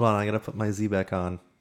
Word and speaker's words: Xebec: X-E-B-E-C is a type of ship. – Is that not... Wait. Z? – Well, X-E-B-E-C. Is that how you pronounce it? Zebek, I think it's Zebek. Okay. Zebek, yeah.